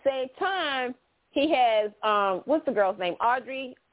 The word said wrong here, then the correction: Audra: I keep saying her name wrong. – Audra? – The one, Audra Charles. – Audra, thank you.